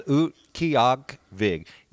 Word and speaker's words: Utqiagvik: I Googled the pronunciation of that. So, yeah, it's Utqiagvik. Utqiagvik.